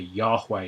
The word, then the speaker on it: Yahweh: This is the Hebrew tetragrammaton, Yud-Heh-Vav-Heh in Hebrew, the Yahweh it's often pronounced.